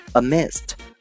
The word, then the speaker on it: amidst: amidst, amidst, A-M-I-D-S-T, A-M-I-D-S-T, 这里面 D 不发音啊。